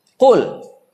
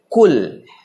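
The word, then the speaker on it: Kul (قُلْ): Kul ya pakai kof. Jadi bacanya jangan pakai kaf biasa tapi pakai kof. Kul.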